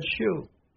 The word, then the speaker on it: shew: And if you saw Cole, who was a Scotsman, in the Lonesome Dove, whenever he used the word show, he said shoe.